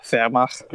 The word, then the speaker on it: Wehrmacht: The people that fetishize the Wehrmacht, or as it's actually pronounced, Wehrmacht.